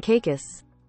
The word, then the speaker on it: Caicos: With the Turks and Cal, uh, Cal, Cal, Cal I never can say it right. Turks and. cow. That's not it. That is not it. That's me mocking. – Turks and Caicos.